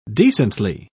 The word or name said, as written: decently